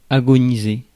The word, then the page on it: agonize: /ˈæ.ɡən.aɪz/